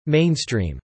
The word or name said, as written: mainstream